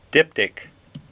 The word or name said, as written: Diptych